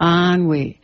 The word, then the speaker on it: Anouilh: And it's by Jean Anouilh. – Anouilh, is that how you pronounce his name? Mm-hmm. Anouilh.